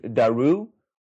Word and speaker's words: Daru: The one was named Daru, where that U sound at the end is a vowel sound that's produced from the back of your mouth, while the name of the other product was Dari.